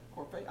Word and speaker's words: Korphe: We are the children of K-O-R-P-H-E. Is that Corfe? Corfe? That's what I'm going to pronounce it.